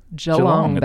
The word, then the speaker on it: Geelong: I think is it pronounced Geelong. – Geelong, I actually Geelong. – Believe. – Geelong. – Geelong, baby.